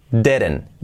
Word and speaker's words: didn't: La E como una E, didn't, didn't, didn't, didn't, didn't. Y la segunda D prácticamente se hace una R suave, didn't, didn't. La T de didn't no se pronuncia, didn't know.